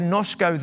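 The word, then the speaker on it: nosko (γινώσκω): The word know there in the Greek is the word, it's spelled G I N O S K O, ginosko. That's what it looks like, but you pronounce it nosko, okay?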